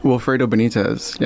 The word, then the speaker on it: Wilfredo Benitez: Wilfredo Benitez, yes.